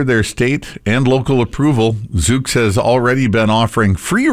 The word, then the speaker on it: Zoox: Amazon's fully autonomous Zoox, Z-O-O-X, how would you pronounce that? – Zoox.